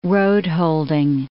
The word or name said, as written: roadholding